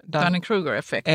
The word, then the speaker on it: Dunning-Kruger-effekten: Hur uttalas det där? – Dunning-Kruger-effekten. – Är det Dunning-Kruger-effekten? – Ja, Dunning-Kruger-effekten är att...